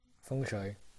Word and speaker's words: feng shui: I guess somewhat related to kind of like putting stuff around your room and house is like feng shui. I don't, how do you say that in English? – Feng shui.